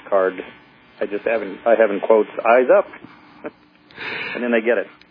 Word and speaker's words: IZUP: It's spelled I-Z-U-P, as in Indigo Zebra Upsilon Paul, I-Z-U-P, and it's pronounced Eyes Up, as looking up to space. That's how it's pronounced.